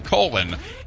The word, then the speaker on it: Colon: Do you know that there's a town in southwestern Michigan called Colon? They literally pronounce it Colon, and it's the magic capital of the world.